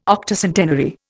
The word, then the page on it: Octocentenary: Indian